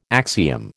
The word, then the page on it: axiom: American English